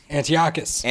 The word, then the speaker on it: Antiochus: We're talking about that dude Antiochus. How do you say his name? – Antiochus.